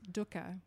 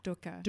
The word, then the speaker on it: dukkha: or what he calls dukkha, which I love that word, dukkha. – Dukkha. – Dukkha. – And we're saying it right.